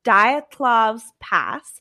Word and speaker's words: Dyatlov's Pass: Okay, so it's pronounced Dyatlov's Pass.